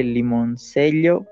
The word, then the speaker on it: Limoncello: Se, escribe, se lee limoncello, pero se pronuncia limoncello, es italiano.